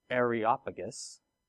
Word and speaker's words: Areopagus: And it says, and they took him and brought him to the, how do you say that again? Areopagus. Yes, thank you.